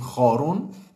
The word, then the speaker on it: خارون: کرون چرون در زبانهای مختلف متفاوت اسم این موجود پرونانس میشه تلفظ میشه حالا تو فارسی مثل اینکه بهش میگیم خارون